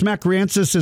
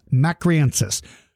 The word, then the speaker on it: mcraeensis: Its last name is spelled MCRAEENSIS. Tyrannosaurus macriensis. For all of you that are listening,